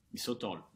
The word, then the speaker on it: Sotol: And we'll be talking about a different Mexican spirit called Sotol. Did I say that right? – Sotol, yes.